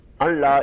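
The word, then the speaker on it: أن لا: تشہد, اب ان کی وجہ سے نصب آئی ہے کہ تو گواہی دے, ان لا الہ الا اللہ, ان یہاں لام کے ساتھ آئے تو نون پڑھا نہیں جاتا ویسے تلفظ میں,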